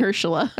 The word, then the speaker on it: Mahershala: Mahershala. Did I say it right? – Mahershala. – I said it right that time.